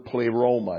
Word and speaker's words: pleroma: It comes from the word pleroma. It's spelled P-L-E-R-O-M-A. Long E, long O. Pleroma.